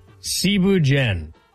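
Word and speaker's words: Thieboudienne: All right, first off, Cebu Jen. It's spelled like Thibu Dien.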